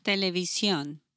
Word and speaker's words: televisión: And how do you say television? – Televisión.